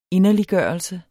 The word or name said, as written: inderliggørelse